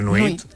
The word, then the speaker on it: nuit: La nuit. – La nuit. – Nuit, muy bien. O sea, wit, nuit, perfecto.